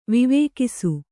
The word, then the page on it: ವಿವೇಕಿಸು: vivēkisu